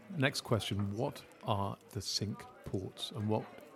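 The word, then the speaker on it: Cinque Ports: It is also one of the sink ports. Now, I'm using the English pronunciation. The English pronounce it. It's actually a French word. It's supposed to be cinq port. And the English say the sink ports.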